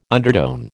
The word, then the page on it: undertone: American English